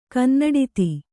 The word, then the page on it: ಕನ್ನಡಿತಿ: kannada